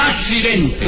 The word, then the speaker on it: accidente: El occidente. – Sí. – El accidente. – Tú lo viste, ¿no? – ¡No lo vi! Ah, bueno. Pero se dice así.